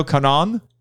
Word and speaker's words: Kanon: Kanon? – Yeah, Kanon. Yeah. – Kanon. Kanon. Okay. – The like double consonant, you're supposed to hold it a little bit.